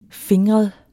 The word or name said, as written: fingret